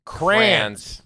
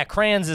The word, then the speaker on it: crayons: Huh, yeah, crayons isn't good. – Well, some people say crayons, pronounce the Y.